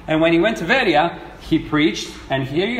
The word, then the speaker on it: Veria: Well, it's a terrible pronunciation, Veria.